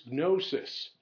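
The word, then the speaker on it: gnosis (γνῶσις): And as you take a look here, when you look for the word for knowledge here, it's gnosis. Now, I try to pronounce the G and the N together. It's probably a silent G, but it's probably gnosis. But I always try to go with gnosis.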